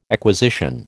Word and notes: acquisition: American English

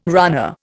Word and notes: runner: Indian

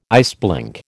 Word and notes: iceblink: American English

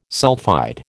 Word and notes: sulphide: American English

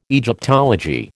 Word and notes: Egyptology: American English

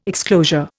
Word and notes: exclosure: Indian

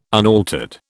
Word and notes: unaltered: British English

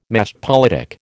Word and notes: machtpolitik: American English

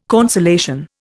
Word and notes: consolation: Indian

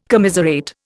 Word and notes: commiserate: Indian

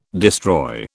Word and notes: destroy: British English

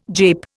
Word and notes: jape: Indian

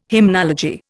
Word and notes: hymnology: Indian

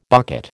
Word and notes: bucket: American English

